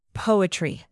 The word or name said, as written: poetry